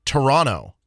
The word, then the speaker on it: Toronto: Note to our American broadcast friends, Toronto is pronounced Toronto. The second T is silent.